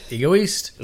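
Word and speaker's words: Égoïste: All right, I've got Chanel Egoist. – Yep, Egoist.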